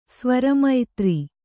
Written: ಸ್ವರಮೈತ್ರಿ

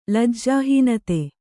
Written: ಲಜ್ಜಾಹೀನತೆ

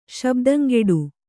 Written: ಶಬ್ದಂಗೆಡು

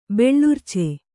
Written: ಬೆಳರ್ಚು

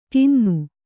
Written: ಟಿನ್ನು

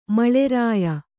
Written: ಮಳೆರಾಯ

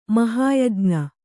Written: ಮಹಾಯಜ್ಞ

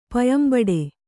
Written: ಪಯಂಬಡೆ

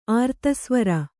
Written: ಅರ್ಧಸ್ವರ

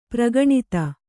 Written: ಪರಿಗಣಿತ

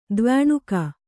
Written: ದ್ವ್ಯಣುಕ

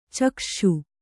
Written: ಚಕ್ಷ್ಷು

